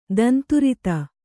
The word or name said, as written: ದಂತುರಿತ